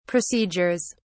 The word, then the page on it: procedures: English; British English